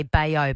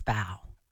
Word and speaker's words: bao: So I sort of get it's more every man to say bayo bun, even if you know it's bao.